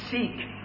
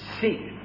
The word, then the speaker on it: Siq: Because the city of Petra could be entered only by a narrow sikh, as the Arabs call it. It's spelled S-I-Q.